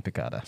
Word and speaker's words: piccata: Yeah, you're going to be eating pizza rolls and chicken pitaya. – It's pronounced chicken piccata.